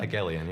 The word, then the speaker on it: Hegelian: The fucked up thing is what we were just talking about before, Hegelian dialectics. Is that how you say it? – Hegelian, yeah.